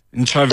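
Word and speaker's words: Ntshavheni: Minister of Presidency is a Minister Machinveni. Did I pronounce it right? – Nchaveni. – Did I pronounce it right? – Minister in the Presidency, Nchaveni, yes.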